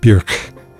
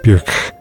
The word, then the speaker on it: Björk: So if I can say it right, it's something more like Björk. The K is K. Björk.